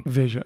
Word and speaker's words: Veja: and he wrote an article in Veja magazine. – Veja, yeah. – I think I pronounced it right.